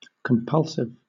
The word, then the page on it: compulsive: British English